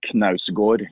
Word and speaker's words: Knausgård: And for the grand finale, his fucking last name. How do you say that? – Fucking last Knaus. Knausgård.